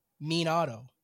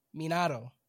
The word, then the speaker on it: Minato: He said Minato, not... Oh, no. It's Minato, not Minato. Minato.